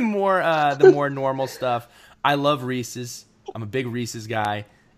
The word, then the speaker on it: Reese's: I think, for the record, you said it right, and I got to give you so much. It is, for everybody out there, I really want you to hear this. It is Reese's.